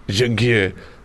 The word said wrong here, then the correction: Juncker: but she's put Y-O-O dash N-K-E-R. – Juncker.